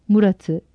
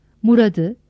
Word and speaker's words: Murat'ı: Ancak kelimeler Ahmet'i, Halit'i, Mehmet'i, Murat'ı, Recep'i, Gaziantep'i, Sinop'u, Zonguldak'ı şeklinde telaffuz edilmez. Ahmet'i, Halid'i, Mehmet'i, Murad'ı, Recep'i, Gaziantep'i, Sinop'u, Zonguldak'ı şeklinde telaffuz edilir.